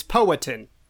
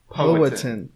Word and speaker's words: Powhatan: According to this video on YouTube that says, that's how to pronounce the name of the tribe, it's Powhatan. – Powhatan.